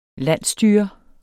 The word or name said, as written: landsstyre